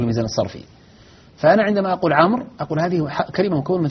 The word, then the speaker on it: عمرو: الواو في عمر والألف في مئة هذه أحرف، وكذلك الألف في التي تذكر بعد واو الجماعة يسمونها الفارقة. – نعم. – هذه كلها أحرف، أولا لا تعد من الجملة من الكلمة التي هي فيها، ولا تنطق، وحتى عند الميزان الصرفي إن من يعرف الميزان الصرفي لا توزن.